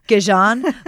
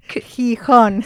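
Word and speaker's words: Gijón: Gijon, but no, it's what? – Gijon.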